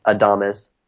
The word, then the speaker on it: Adames: it is Adamas, Adames, and uh, that seems to be right.